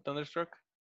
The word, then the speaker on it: Thunderstruck: Y la primera canción que escuché, si igual no recuerdo, güey, fue la de Thunderstruck. ¿Se ah, pronuncia así? Thunderstruck.